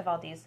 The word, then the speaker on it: Valdez: It is pronounced Valdez. We did double check because we kept calling it Valdez.